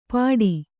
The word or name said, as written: ಪಾಡಿ